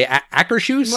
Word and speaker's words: Akershus: Akershus.